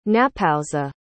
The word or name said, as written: napowsa